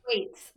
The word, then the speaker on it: Thwaites: And am I saying that right? Thwaites? – Thwaites. Like it's a little bit more like Thwaites.